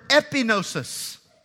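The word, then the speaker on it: epignosis: It's the word epinosis. So you take G-N-O-S-I-S and you add three letters to the front of it, E-P-I. That's how we would spell it or say it in English, epinosis.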